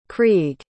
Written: creagh

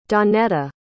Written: dawnetta

